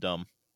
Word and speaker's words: dumb: D-U-M-B. – No, that's dumb. It's still in there. – No, it's just dumb. – Exactly. You just said it. – Yeah, but there's no B sound at the end. It's dumb.